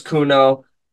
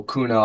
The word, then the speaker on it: Kono: Yeah, so on all platforms, all social media, you can find me, my rap name's Kuno, but some of my social media. – Can you spell that for people so they know? – K-O-N-O, Kuno.